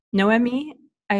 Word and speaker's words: Noemi: I did Noemi, but I think Noemi sounds a little bit more authentic. I think I Americanized it with Noemi.